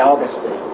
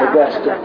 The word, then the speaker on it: Augustine: However, when we get to Augustine, I don't say Augustine because he did. It's Augustine. But if you go to Florida, Augustine.